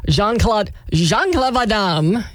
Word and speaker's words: Jean-Claude Van Damme: But after that movie, he went on to be with Jean-Claude Van Damme in the movie Bloodsport. You have to say it like that.